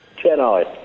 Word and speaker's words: Chennai: We'll speak to you from Chennai, or as it's correctly pronounced... – Chennai.